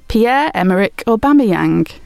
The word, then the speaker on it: Pierre-Emerick Aubameyang: How do you spell Pierre-Emerick Aubameyang?